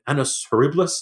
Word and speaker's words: annus horribilis: But I made the point, like, we've just been through an annus horribilis.